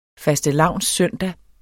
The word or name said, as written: fastelavnssøndag